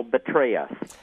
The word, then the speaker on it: Petraeus: You said General Petraeus. You need to pronounce that more carefully because I agree he's betrayed us, you don't, and you probably should pronounce the P more clearly.